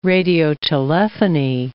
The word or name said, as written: radiotelephony